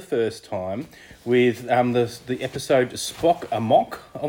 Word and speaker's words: Spock Amok: It is indeed Spock Amok, yes. And my basis for that is entirely because it rhymes.